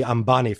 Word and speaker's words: Ambani: Now, the company is controlled by the Ambani family. Am I saying that right? – Right.